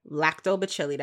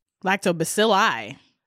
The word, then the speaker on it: Lactobacilli: Lactobacilli. – That's what Sam said. That's what it is. – Let me double check on this nigga Google real quick, though. – Lactobacilli.